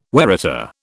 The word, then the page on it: waratah: British English